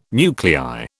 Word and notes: nuclei: British English

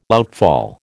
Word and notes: outfall: American English

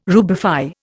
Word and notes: rubify: Indian

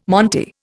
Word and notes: monte: Indian

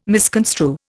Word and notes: misconstrue: Indian

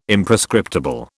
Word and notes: Imprescriptible: British English

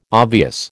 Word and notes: obvious: American English

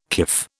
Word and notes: kif: British English

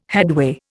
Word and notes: headway: Indian

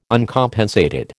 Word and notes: Uncompensated: American English

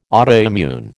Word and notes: autoimmune: American English

